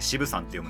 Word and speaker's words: 渋山: 渋山って書いて渋さんって読むんですけど、その渋山ですそうっていうところでね、意外とそういろんなところというか、オリジナルで散りばめたりしてるんですよね。